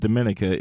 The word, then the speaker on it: Dominica: The correct pronunciation of the country of Dominica is actually Dominica. So it's Dominica.